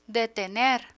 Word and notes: detener: spanish

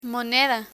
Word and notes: moneda: Spanish

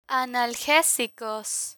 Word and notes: analgesicos: spanish

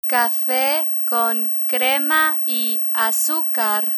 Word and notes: cafe con crema y azucar: spanish

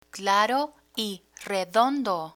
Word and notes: claro y redondo: spanish

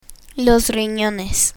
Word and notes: rinones: spanish

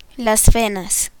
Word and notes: venas: spanish

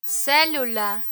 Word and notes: celula: spanish

